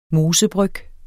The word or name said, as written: mosebryg